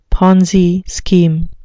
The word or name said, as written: Ponzi scheme